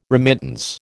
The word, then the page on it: remittance: American English